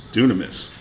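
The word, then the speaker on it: dunamis (δύναμις): The Greek word translated power here is dunamis. I think that's how you pronounce it. It's dunamis.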